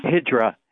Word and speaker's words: Hijra: And are we pronouncing it correctly? Hijra to America? – Hijra. Hijra, yes.